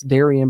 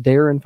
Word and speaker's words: Darian: I know it's spelt like it's Darian, but it's actually pronounced Darren for the first name and last name DeVries there for the West Virginia men's basketball new head coach, 23rd in program history.